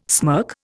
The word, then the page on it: smirk: Indian